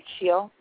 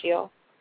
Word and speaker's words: Shiel: You know, I don't know why the universe put these words I can't pronounce, but her name is S-H-I-E-L, so the I would be silent, right? Scheel? Lisa Scheel? – Sure.